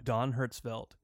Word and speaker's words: Don Hertzfeldt: This is a short animated film by Don Hertzfeldt. – Yes. – Did I pronounce that? – Yeah. Don Hertzfeldt.